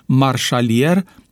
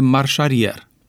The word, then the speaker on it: marșarier: Dar ce spuneți despre substantivul jantă, pe care majoritatea șoferilor îl pronunță geantă, tot așa cum le vine mai ușor să pronunțe marșalier în loc de marșarier?